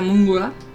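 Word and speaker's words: Mgła: Que bien pronunciado sería Mungla. – Ah, sí. – Uh-huh. Que no sé yo pronunciarlo, pero algo así.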